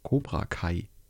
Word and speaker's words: Cobra Kai: Cobra Kai. Spricht man die eigentlich so aus? – Yes. – Cobra Kai. Yes. So wie Kai aus der Kiste.